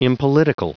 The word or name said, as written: impolitical